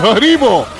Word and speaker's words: Haribo: Haribo? Is that the candy brand that does the gummy bears? Is that how you say it? – Haribo.